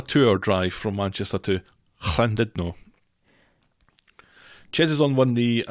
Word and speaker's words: Llandudno: And this is leaving the coast clear for Ches and Gemma to have their day out to Llandudno. – You pronounce that quite well.